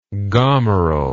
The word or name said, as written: gomerel